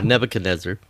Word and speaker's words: Nebuchadnezzar: Nebuchadnezzar. – Is that how it's pronounced? – No, Nebuchadnezzar. – Nebuchadnezzar, right? That's what I thought.